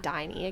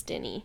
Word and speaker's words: Diny: I think it's Denny. – Let me see. – It's D-I-N-Y. – D-I-N-Y. – It's not Diney. It can't be. It's Denny.